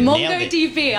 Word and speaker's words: MongoDB: Yep, of course, Meta, M-E-T-A. It's actually slightly down after a surge last week. We see Meta tapped, I don't know how to say the company name, MongoDB, very good. – MongoDB. I was like, is that the name?